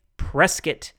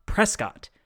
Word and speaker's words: Prescott: Trimble notes that while the historian's name was Prescott, those in Arizona soon started pronouncing the town's name as Prescott. So for all of my out-of-state listeners out there, if you ever visit, remember to say Prescott, not Prescott. Otherwise, everyone will know that you are not from around these parts.